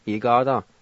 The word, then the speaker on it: igada: Number two, some, Igada.